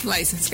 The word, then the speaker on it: License: ¿Licencia? – License, gracias, perdón.